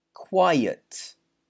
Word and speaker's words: quiet: Next, Q-U-I-E-T. Quiet. Quiet. So make sure you pronounce the qua here. Quiet. And it's got a y sound.